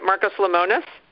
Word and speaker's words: Marcus Lemonis: Marcus Lemonis? – Yes.